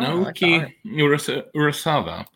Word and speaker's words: Naoki Urasawa: Oh, Naoki Urasawa, which is the creator of Monster.